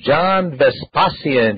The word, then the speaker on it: John Vespasian: I want to take this opportunity, and let's welcome to the show John Vespasian.